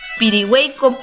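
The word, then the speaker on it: Pirihueico: Pirigüey con y Todos dicen pirigüey con puyi. Y no existe pirigüey. Es pirigüey co y Son lagos.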